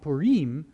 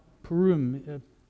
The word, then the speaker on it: Purim: I've been calling it Purim, but recently said, no, Purim is the better pronunciation.